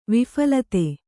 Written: ವಿಫಲತೆ

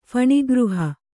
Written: ಫಣಿಗೃಹ

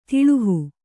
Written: ದುಲ್ಲಹ

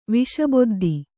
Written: ವಿಷಬೊದ್ದಿ

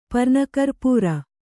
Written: ಪರ್ನಕರ್ಪೂರ

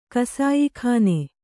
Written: ಕಸಾಯಿಖಾನೆ

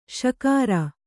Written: ಶಿಖರಿ

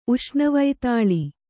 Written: ಉಷ್ಣವೈತಾಳಿ

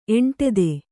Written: ಎಂಟೆದೆ

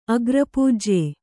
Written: ಅಗ್ರಪೂಜ್ಯ

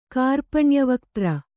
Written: ಕಾರ್ಪಣ್ಯವಕ್ತ್ರ

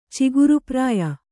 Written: ಚಿಗುರುಪ್ರಾಯ

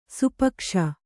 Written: ಸಾಪೇಕ್ಷೆ

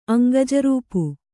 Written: ಅಂಗಜರೂಪು

